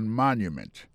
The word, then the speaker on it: monument: accent sur accent encore ou après que mot Washington là accent tombé sur premier syllabe là pour mot monument il tombé sur premier syllabe là de Washington Monument monument c'est comme ça dit monument en anglais alors place ça c'est place monument ou c'est obélix là.